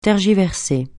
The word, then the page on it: tergiverser: francais